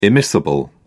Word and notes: immiscible: British English

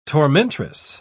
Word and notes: tormentress: British English

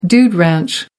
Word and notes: dude ranch: American English

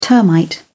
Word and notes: termite: British English

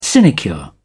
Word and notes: sinecure: British English

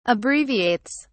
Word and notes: abbreviates: British English

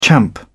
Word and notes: champ: British English

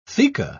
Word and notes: theca: British English

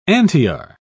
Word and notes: antiar: British English

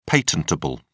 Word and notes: patentable: British English

